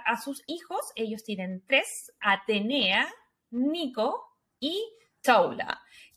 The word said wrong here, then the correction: Tula: Tula. Ya, eso. La pronunciación, chiquillo, es tula.